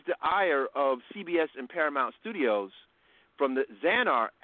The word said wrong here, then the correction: Axanar: Axanar, pardon me,